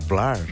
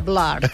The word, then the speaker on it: Blur: I ja ho vam fer la setmana passada amb Oasis, i aquesta setmana ho farem amb els Blur o Blur. Perquè, val, clar, si ho volguéssim pronunciar bé, hauríem de dir Blur.